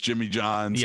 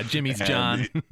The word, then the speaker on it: Jimmy John's: It's just the way, you know, like he mispronounces Jimmy John's. – Yeah, Jimmy's John.